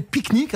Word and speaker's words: Picnic: Elle s'appelle Picnic Alors le nom vous dit peut-être rien, c'est vous le prononcez comme un pique-nique à la campagne.